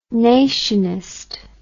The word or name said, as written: nationist